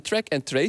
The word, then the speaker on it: track-and-trace: Een track-and-trace systeem, zeg ik dat goed? – Ja, dat klopt inderdaad.